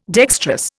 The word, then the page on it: dextrous: Indian